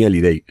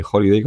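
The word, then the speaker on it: Hallyday: Sí, sale, es muy chula porque sale Jan Rockford y, y bueno, yo lo voy a pronunciar, eh, ellos dicen Johnny Eliday que es como lo dicen ellos, eh, con, con, con, Johnny o con Johnny Holiday, como tú dices, que es el famoso cantante este francés, con esa voz, ese bozarrón que tienen.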